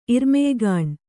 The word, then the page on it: ಇರ್ಮೆಯ್ಗಾಣ್: Kannada